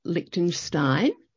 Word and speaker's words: Liechtenstein: Jess, tell me, as we can tell from your accent, it doesn't sound like you're originally from Liechtenstein. Am I pronouncing that right? – That's right.